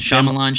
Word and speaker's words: Shyamalan: I always say his name wrong. – Mm-hmm. – Did I say that right? M. Night Shyamalan. – Shyamalan. – I would say Shyamalan.